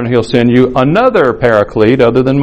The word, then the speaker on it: paraclete: Parakletos is the Greek. It's often pronounced paraclete.